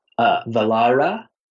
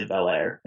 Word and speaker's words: Velare: And I think the technical pronunciation, which I feel so pretentious to say, is like Velara. But I say Velare, and it's really American, and I get made fun of a lot.